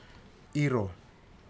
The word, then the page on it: iro: futuna aniwa